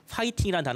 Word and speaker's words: 화이팅: 한국엔 F 발음이 없기 때문에 네. 이걸 호나 후로 받아들인 경우들이 있었거든요 예전에 네, 네. 그래서 화이팅이라는 단어를 화이팅으로 받아들였고 네.